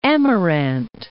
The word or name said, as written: Amirante Islands